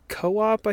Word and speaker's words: KO_OP: This is by, I think it's Co-op, I think is how it's pronounced. It's KO_OP.